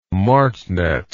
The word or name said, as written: martnet